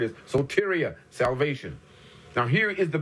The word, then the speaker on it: soteria: It's pronounced soteria. It's spelled S-O-T-E. R-I-A, soteria, S-O-T-E-R-I-A.